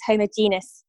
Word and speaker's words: homogenous: And I know you pronounce that differently. I think it's homogeneous to say it in the States. But yeah, I literally... – Oh, no. We say it the same way you do. Homogenous.